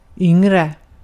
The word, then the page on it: yngre: /ˈʏŋ.rə/